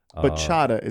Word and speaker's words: Bachata: Bachata is how you say it.